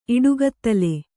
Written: ಇಡುಗತ್ತಲೆ

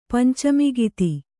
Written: ಪಂಚಮಿಗಿತಿ

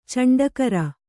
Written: ಚಂಡಕರ

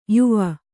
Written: ಯಾವ

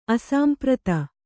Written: ಅಸಾಂಪ್ರತ

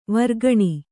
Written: ವರ್ಗಣಿ

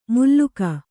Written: ಮುಲ್ಲೂಕ

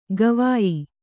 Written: ಗವಯ

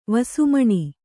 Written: ವಸುಮಣಿ

